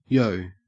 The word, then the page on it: yo: Australian English